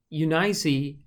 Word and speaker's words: Eunice: And it has Eunice as a three-syllable word with the accent on the middle syllable, a long I and a long E.